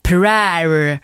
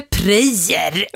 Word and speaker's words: prayer: Ordet prayer, prayer, rimmar på hair, inte prayer, har de skrivit.